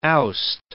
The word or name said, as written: oust